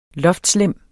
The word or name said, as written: loftlemme